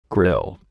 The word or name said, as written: grill